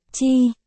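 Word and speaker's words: chī: Cách chơi chữ đồng âm, chữ ăn và chữ si mê trong tiếng Trung đều đọc là chi.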